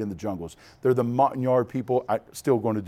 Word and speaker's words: Montagnard: And they were of the Montagnard, I'm not saying it right, Montagnard.